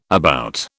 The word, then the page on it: about: British English